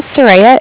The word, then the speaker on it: Soraya: My name is Soraya. It's spelled S-O-R-A-Y-A.